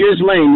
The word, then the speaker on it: Ghislaine: How do you even say her name? Maxwell. Is it Ghislaine? Whatever the hell her name is. – Ghislaine, yeah.